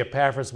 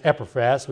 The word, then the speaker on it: Epaphras: Now, it was during this time that this man Epaphras, some people say Epaphras, but probably should be pronounced Epaphras, we read of him in verse 7.